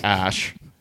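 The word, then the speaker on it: Æ: So X for the unknown variable, AE thing, is elven spelling for AI, artificial intelligence. But it's pronounced ash.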